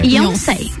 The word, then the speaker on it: Yonsei: Tô pronunciando a... certo? É Yonsei? É. – Yonsei. Yonsei. Yonsei. Yonsei. Uhum, exatamente. Então, foi nisso que eu pensei, não, eu quero algo diferente.